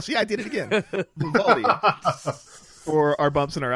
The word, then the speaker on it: Vivaldi: I can never say it right. – Vivaldi. – Vivaldi. I always want to say Bivaldi for some reason.